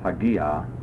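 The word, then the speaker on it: hagia (ἁγία): The word for holy is an adjective, hagias, hagia, hagion. You'll note the accent of the feminine form. I pronounced it as hagia, indicating then that the alpha is long.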